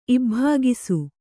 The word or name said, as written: ಇಬ್ಭಾಗಿಸು